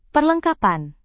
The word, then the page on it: perlengkapan: Indonesian